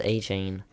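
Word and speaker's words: Achane: Yeah, am I saying his name right? The Dolphins running back? – Or is it Arcane? – I think it's A-Chain.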